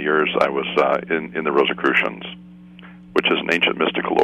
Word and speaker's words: Rosicrucians: but are you familiar with the Rosicrucians? – Rosicrucians. – Okay, Rosicrucians.